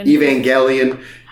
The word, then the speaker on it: Evangelion: Evangelion.